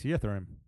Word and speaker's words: Yttrium: It's Yttrium. – Oh, yeah, that's yttrium.